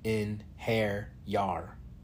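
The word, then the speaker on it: Einherjar: He pronounced the word like in-hair-yar. Inheriar, I repeated.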